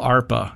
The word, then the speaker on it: ARPA: It sounds a little like DARPA.